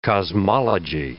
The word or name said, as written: cosmology